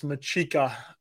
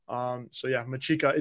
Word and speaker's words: Machika: How do I pronounce my last name? Well, it's Machika. Italian Maltese to be exact, that little island off the coast of Sicily. Machika, intern Joe Machika, is the way you say it.